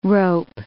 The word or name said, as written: rope